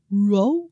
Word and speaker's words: Rouault: Rouault. – R-O-U-A-U-L-T.